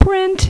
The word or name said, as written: print